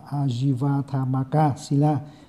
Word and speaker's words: Ājīvaṭṭhamakasīla: Tam giới thì chúng ta vừa thì cái câu tam giới này thì cái câu của nó là cái câu là cái tiếng Pali là Ajiva Athamaka Sila, đọc là Ajiva Athamaka Sila.